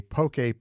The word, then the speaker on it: poke: But never fear, she's here to give us a poke primer. Welcome back to the VPR Cafe. – Hi, Rick. – Okay, am I even pronouncing it correctly? – Yes, poke.